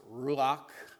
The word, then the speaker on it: ruach: In Old Testament Hebrew, there is a word, It is pronounced ruach.